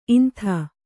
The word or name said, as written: ಇಂತಿ